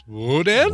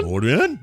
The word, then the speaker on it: Odan: That's how you pronounce that. It's Odan. – Odan.